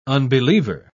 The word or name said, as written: unbeliever